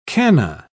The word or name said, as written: canna